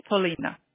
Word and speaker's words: Polina: My name is Polina. – And how do you spell that? – P-O-L-I-N-A.